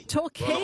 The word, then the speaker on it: Torquay: In Talk, is it? – Torquay. – Well, Torquay. It's spelt talk on my screen, like motor talk. But I thought, it might be Torquay, but okay.